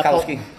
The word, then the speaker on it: Tarkowski: Trzaskowski. But he's actually an English centre-half. But I can never say his name properly. – Tarkowski. – That's a Tarkowski.